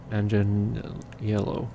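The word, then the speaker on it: Manganiello: No, Mangianiello. Mangianiello.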